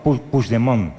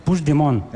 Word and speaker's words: Puigdemont: Me has preguntado antes, ¿cómo se dice este nombre? – Sí, lo voy a intentar. ¿Eh? – No, Puigdemont. – Puigdemont. – Vale. – Puigdemont. – Eso es. – Sí, lo dices como francés, así.